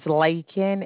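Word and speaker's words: Laken: My name is Lakin. It's L-A-K-E-N.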